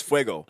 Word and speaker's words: fuego: The order to fire, he says fuego.